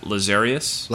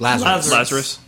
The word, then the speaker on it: Lazarus: Lazarius. – Lazarus.